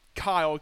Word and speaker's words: Keil: And so to this day now, I write down Kyle, K-Y-L-E, instead of Keil, well, the way it looks like it.